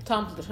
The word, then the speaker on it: Tumblr: Tumblr.